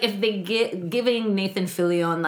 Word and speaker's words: Fillion: Fillion. – He's Canadian. It's Fillion.